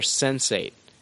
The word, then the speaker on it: Sense8: Michael Straczynski to bring us a 10-episode season for the sci-fi thriller Sense8. – Wow. – It's the word sense and the number 8 pronounced.